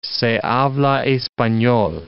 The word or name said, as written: se habla espanol